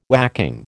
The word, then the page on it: whacking: American English